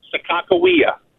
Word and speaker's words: Sacagawea: How do you pronounce Sacagawea again? – Sacagawea.